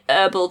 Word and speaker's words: herb: Do Americans say herbal tea? – Probably. – Yeah, they say herb. – Most of them, yeah. – Why? – It's great because... – They don't pronounce the H for some reason.